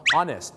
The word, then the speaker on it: honest: 3 คำที่เริ่มต้นด้วยตัว H เนี่ยนะครับที่ไม่ออกเสียงฮอนะฮู้แต่เป็นออ่างหรือเป็นอ๊อเนี่ยนะฮะจะเป็นอีก 1ครับก็คือคำว่า honest นะครับ honest